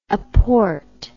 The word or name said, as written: apport